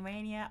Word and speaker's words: Rania: My Facebook is Kitty, last name Rania, R-A-N-I-A.